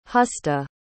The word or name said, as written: Husta